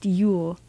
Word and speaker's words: Yeule: I was connected to a new artist, Yule. Their name is spelled Y-E-U-L-E.